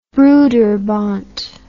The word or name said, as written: broederbond